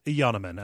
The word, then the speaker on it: Yanneman: So yes, it is Yanneman as opposed to the phonetic pronunciation that we used.